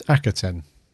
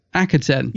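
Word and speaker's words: Akhaten: Is that how you pronounce Akaten? Akaten? – It's Akaten. – Akaten.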